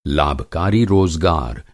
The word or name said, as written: रोज़गार